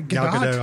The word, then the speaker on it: Gal Gadot: Gal Gadot, I believe.